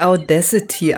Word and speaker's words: Audacity: Genau, man spricht es richtig Audacity aus.